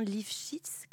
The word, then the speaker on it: Lifshitz: de Sébastien Lifshitz. Comment ça se prononce? Comme ça, ouais.